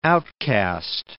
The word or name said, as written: outcaste